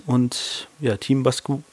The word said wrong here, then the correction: Basquiat: ich kann das gar nicht aussprechen, ey. Basquiat. – Basquiat.